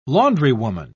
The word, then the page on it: laundrywoman: British English